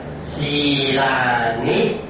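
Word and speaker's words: sīlāni: Đối với chữ A, chữ I thì chữ A đọc mà dòng dài đọc là Si La, mà chữ N, I mà dòng không có dòng ngang, cái dấu ngang thì đọc là Ni, đọc là Si La Ni. Ni còn nghĩa là mình phát âm ngắn mà chẳng có, đừng có thêm dấu hỏi, dấu sắc, dấu nghiền gì cả, cứ dài thì kéo nó dài ra, ngắn thì đọc ngắn.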